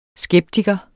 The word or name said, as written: skeptiker